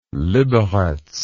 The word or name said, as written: liberec